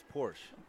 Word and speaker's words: Porsche: The Sha. – It's pronounced Porsche.